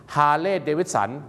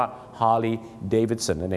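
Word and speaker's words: Harley-Davidson: ฮาร์เลย์เดวิดสันแย่ฮาร์เลย์เดวิดสันต้องเข้าใจนะครับคุยพี่น้องชาวไทยก็ต้องฮาร์เลย์เดวิดสันแต่ถ้าฝรั่งเนี่ยนะครับก็จะออกเสียงว่าฮาร์ลีย์เดวิดสันนั่น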